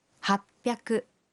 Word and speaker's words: happyaku: Y 800 se dice happyaku.